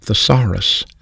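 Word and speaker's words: thesaurus (θησαυρός): Well, this may shock you, but the Greek word for treasure is an English word you probably know is pronounced thesaurus.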